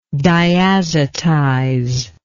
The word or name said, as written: diazotizability